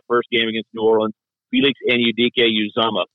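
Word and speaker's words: Felix Anudike-Uzomah: Felix Anudike Uzomah. Say it that way, and you'll be in the top 5% because very few people are pronunciating it the right way. Felix Anudike Uzomah.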